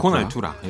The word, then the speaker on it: Con Altura: 로살리아 그리고 제이 발빈의 콘 아, 알뚜라 이렇게 네. 발음하네요. 알뚜라. 콘 알뚜라 콘 알뚜라 발음하는데 네.